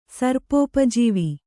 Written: ಸರ್ಪೋಪಜೀವಿ

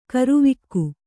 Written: ಕರಿವಕ್ಕಿ

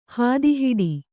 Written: ಹಾದಿಹಿಡಿ

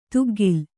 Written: ತುಗ್ಗಿಲ್

